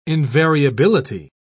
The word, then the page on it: invariability: British English